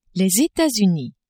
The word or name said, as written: Etats-Unis